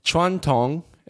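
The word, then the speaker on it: Chuan Tong (传统): Chuan Tong and Chuan Tong means tradition.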